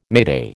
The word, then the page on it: mayday: American English